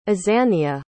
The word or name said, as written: azania